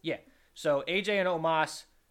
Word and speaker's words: Omos: And it's Omos, I believe. – Oh, you're right. I think it is Omos.